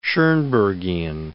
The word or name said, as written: Schoenbergian